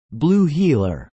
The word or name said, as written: Blue Heeler